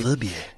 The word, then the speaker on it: Verbier: It's a place called Verbier. I think I'm pronouncing that correctly. – Verbier.